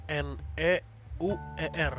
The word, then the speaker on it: Neuer: N E U E R. – Nah, N E U E R kan. – Iya. – E EU tuh kalau di Jerman dibacanya OI Oi.